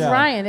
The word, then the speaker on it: Ryan: I think you go hard with the B. You're like, Brian. Well, Ryan. You make it a two-syllable name. Anyway. – No, it is Ryan. It's not Brian.